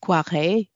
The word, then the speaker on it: Koyré: his mentor and friend Koyré. Uh, I think some people pronounce it Poiré.